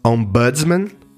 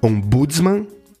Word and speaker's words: Ombudsman: Esse cargo se chama Ombudsman, bem como se escreve, O-M-B-U-D-S-M-A-N. Você pode ouvir as pronúncias mais originais, Ombudsman, ou como paroxítona, Ombudsman, ou ainda, como a gente usa aqui no Brasil, Ombudsman.